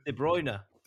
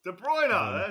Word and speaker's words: De Bruyne: Nah, it's De Bruyne. – De Bruyne, that's all right. – De Bruyne.